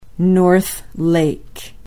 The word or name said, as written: north lake